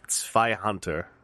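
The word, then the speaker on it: Zweihänder: and then you can have like a Zweihander style, like real big sword or something crazy. Zweihander? – Oh, jeez. That's okay. That's what? All right. That's very Western. That's a very West. It's Zweihander.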